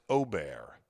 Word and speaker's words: Åberg: Ludvig Åberg, pronounced Ober.